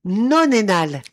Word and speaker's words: nonénal: Trois syllabes. Nonénal. – Nonénal. – Sauf que ça s'écrit N-O-N-2-énal. Mais ils le mettent en ensemble. Nonénal. N-O-N-E-N-A-L. Ils retirent le 2 au milieu. Mais c'est nonénal.